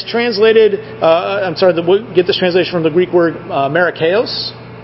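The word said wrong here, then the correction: makarios (μακάριος): or makarios.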